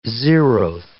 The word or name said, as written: zeroth